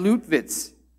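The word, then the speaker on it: Lüttwitz: General Heinrich Freyer von Ludwitz. Is that pretty good? Anyone have a better German accent? von Luttwitz, okay?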